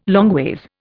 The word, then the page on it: longways: Indian